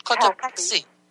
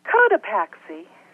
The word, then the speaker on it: Cotopaxi: Cotopaxi. – How do you say that? Where are you from? – Cotopaxi. – How do you say that? – Cotopaxi. – Oh, well, I knew that. Cotopaxi.